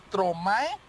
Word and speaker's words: Stromae: Mi hit de hoy suena así, suena, bueno, ahí va la pronunciación, es que yo no parle francés, así que es Stromae.